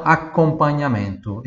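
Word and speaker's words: accompagnamento: Em português de fala acompanhamento, Italiano, acompanhamento. Esse A é um pouquinho mais aberto, tem dois C ali. Acompanhamento, e aí se escreve com GN.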